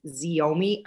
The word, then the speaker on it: Xiaomi: So Carbon TV is its own channel on like 9 different, like Local Now, and I think you pronounce it Xiaomi, XIOMI.